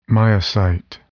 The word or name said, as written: myocyte